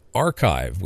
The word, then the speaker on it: arXiv: Now, again, I will say to be fair, uh, these astronomers are, are, publishing on the arXiv, which is spelled A R X I V dot org,